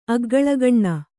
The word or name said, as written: ಅಗ್ಗಲಗಣ್ಣ